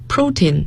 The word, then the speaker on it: protein: 我们叫 protein， protein 要注意它的发音，重音在第一个音节，蛋白质。